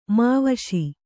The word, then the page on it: ಮಾವಶೀ: Kannada